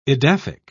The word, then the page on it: edaphic: British English